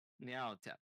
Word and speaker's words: Nyarlathotep: Nyarlathotep. Did I pronounce that right? – Nyarlathotep.